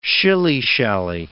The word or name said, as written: shilly-shally